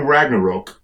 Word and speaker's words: Ragnarok: During Ragnarok, which is actually how it's pronounced apparently,